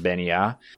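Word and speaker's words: Bean Nighe: So it's spelt Bean Nigh, so BEANNIGHE. But is pronounced Beni Yah.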